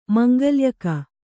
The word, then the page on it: ಮಂಗಳ್ಯಕ: kannada